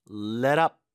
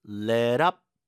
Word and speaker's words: let up: Let up 중간에 T는 또 리을 발음돼서 let up, let up 이렇게 발음되죠.